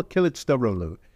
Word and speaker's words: Kılıçdaroğlu: Darulu? I'm pronouncing that wrong. – Kemal Kılıçdaroğlu is the opposition. – Kılıçdaroğlu.